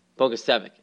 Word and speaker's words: Bogusevic: What did we agree on before the show? – We agreed on Bogusevic. – Bogusevic, that's right. Bogusevic. Brian Bogusevic. Almost sounds like it looks.